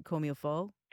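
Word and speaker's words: comme il faut: Yeah, like the, how do you pronounce it, comme-il-faut? – Comme-il-faut, exactly.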